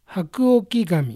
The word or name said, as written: Hakuokigami